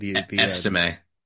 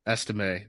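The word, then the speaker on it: Estimé: I thought he was more fluid than Estime, I believe that's how you say his name. – Estime. – Estime, the Notre Dame running back.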